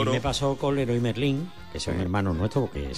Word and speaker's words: Leroy Merlin: Pero efectivamente se pronuncia Leroy Merlin.